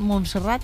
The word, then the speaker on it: Montserrat: En la agenda de hoy tiene previsto acudir a la cárcel de Brians, visitar Montserrat y celebrar una misa en la Basílica de la Sagrada Familia. ¿Lo he dicho bien? ¿Montserrat? – Sí, Montserrat.